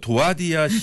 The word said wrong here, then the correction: 두아디라: 목사님 잠깐만요. 네. 두아디라 시요. 두아디라 시.